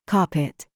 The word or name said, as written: PALM